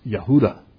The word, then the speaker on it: Yehuda: In English, we pronounce it Judah, but in Hebrew, it's Yehuda. There's no J sound, it's a Y.